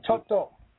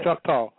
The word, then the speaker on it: Choctaw: Found out she was a Chickatow, if I'm pronouncing the word right. – Right, Choctaw. Yeah. – Choctaw.